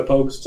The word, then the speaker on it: Pogues: It's, nope. No, it's like, P-O-U and P-O-G-U-E-S. Pogues. – Pogues. – Pogues. – Like Vogue, but like Pogues.